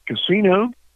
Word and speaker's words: Casino: Casino. – How do you spell it? – Have you been here before? – C-A-S-I-N-O.